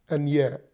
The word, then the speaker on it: aner: All right, now, there are other meanings to the word man, such as the word aner, A-N-E-R, which is spelled or pronounced anier, an-ier.